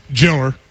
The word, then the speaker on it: jailer: Now, for jailer in Bell County, or as we pronounce it, You're from Bell County. Jiller.